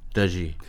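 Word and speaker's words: Tajy: se escribe Tají hmm. con J, Y, pero me han dicho que se pronuncia Tají. Tají.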